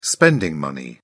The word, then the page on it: spending money: British English